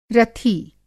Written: रथी

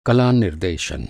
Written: निर्देशन

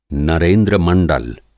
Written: नरेंद्रमंडल

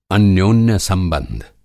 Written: अन्योन्य-संबंध

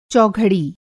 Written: चौघड़ी

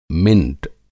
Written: मिंट

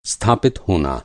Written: स्थापित

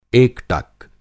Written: एकटक

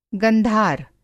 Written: गंधार

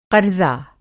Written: क़र्ज़ा

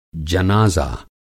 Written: जनाज़ा

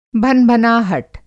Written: भनभनाहट

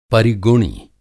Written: परिगुणी

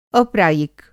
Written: अप्रायिक

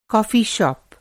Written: कॉफी शॉप

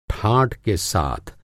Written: ठाट

